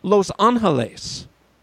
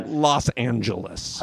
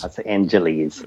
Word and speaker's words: Los Angeles: That said, I have to acknowledge that this very podcast originates from a fair city that is not pronounced Los Angeles. – That's true. – It's pronounced Los Angeles. – Los Angeles. – Right. – Not Los Angeles.